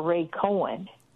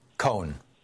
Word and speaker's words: Cohn: Then finally McCarthy and Ray, you said Ray Cohn or Ray Cohen? – Cohn. One syllable. – Cohen. Okay. – Cohen. – C-O-H-N.